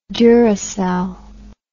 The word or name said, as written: Duracell